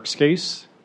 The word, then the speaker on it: Scace: But hello, my name is Eric Skase, and it's spelled S-C-A-C-E, and there's only one, so it's an easy hit.